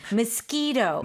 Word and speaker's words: mosquito: Mosquito. – Mosquito. – It does sound like a K.